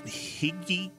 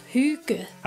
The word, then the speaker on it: hygge: It's that time of year to get cozy, or as the Danes say, higgy, hi-gigi. – It's pronounced hygge.